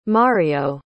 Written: Mario

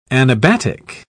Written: anabatic

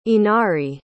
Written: inari

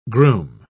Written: groom

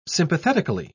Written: sympathetically

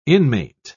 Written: inmate

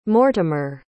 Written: mortimer

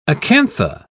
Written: acantha

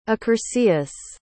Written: accursius

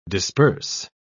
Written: disperse

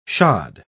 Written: shod

